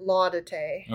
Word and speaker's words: Laudate: So the Laudate Psalms, which I've decided to pronounce it Laudate. – Okay. Yep. – Because the other way sounds too much like la-da-dee, la-da-da, yeah.